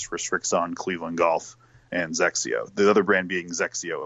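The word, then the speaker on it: XXIO: It's like it sounds kind of like Sexio, but with a Z instead of an S, like a Zexio.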